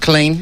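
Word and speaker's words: Killeen: Am I pronouncing that right? – Colleen, that's right.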